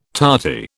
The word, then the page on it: tarty: British English